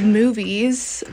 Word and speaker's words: Moveys: It's called Movies, but it's spelled... Sorry, I'm reaching across for my notebook because I forget when the album came out. It's called Movies. It's MOVEYS.